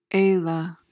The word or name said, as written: ala